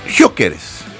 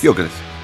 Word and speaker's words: Gyökeres: ¿cómo se dice su apellido? – Gyökeres. – ¿Gyökeres? – Gyökeres.